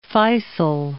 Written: Faysal

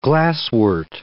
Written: glasswort

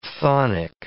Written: chthonic

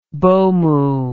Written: mbomu

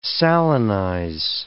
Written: salinize

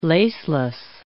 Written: laceless

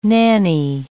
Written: nannie

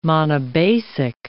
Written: monobasic

